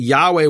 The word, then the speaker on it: Yahweh: And Rayland in 1707, he talked about how the Samaritans preserved the true pronunciation of Yahweh, of the name Yud-Heh-Vav-Heh.